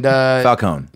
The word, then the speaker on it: Falcone: And Falcone. – Yeah. I pronounce the E. That's how the dwarves say it.